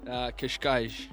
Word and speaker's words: Cascais: Cascais.